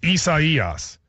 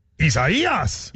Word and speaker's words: Isaías: People are saying, you place the syllable on the emphasis on the wrong syllable. It goes on the third syllable. So it's Isaias. So I want to say Isaias. – Isaias. – Isaías.